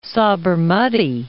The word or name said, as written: Sabarmati